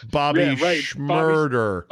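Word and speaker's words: Bobby Shmurda: Rhymes with murder. Bobby Schmurder.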